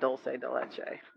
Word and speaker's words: dulce de leche: But I ate a lot of dulce because I ate a lot of dulce de leche. – Is that how you say it? Dulce de leche? – Yeah. Well, that's how I say it.